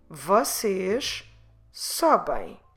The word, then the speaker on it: sobem: So we'll have to say now for plural you, vocês, vocês sobem. They, eles sobem. And feminine they, elas sobem. So you see what happened here. We changed from you, pronouncing U, into O, pronouncing O, which is actually different.